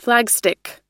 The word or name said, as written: flagstick